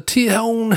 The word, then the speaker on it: Tejon: It's from the Tejon, I think the J is silent, the Tejon Street Corner Thieves giving us I Put a Spell on You.